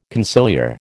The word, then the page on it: conciliar: American English